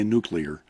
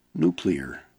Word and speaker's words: nuclear: I always have to say that carefully because I have this tendency to say nuclear. No, nuclear.